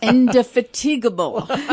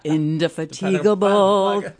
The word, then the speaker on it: indefatigable: Indefatigable. How do you say that? – Indefatigable. Indefatigable. That's what she is. – I don't know that that's how it's pronounced, but I like the song.